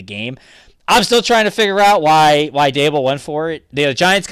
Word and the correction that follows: Daboll: I'm just impressed that you finally, after all season, pronounced Dable's name correctly. – I didn't. It took us all year long. I wish I hadn't, because that's kind of my thing, not to pronounce them right. But anyway, Brian Daboll, damn it.